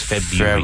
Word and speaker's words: February: No, it's February. – February. – Yeah. Spelled February, but pronounced February.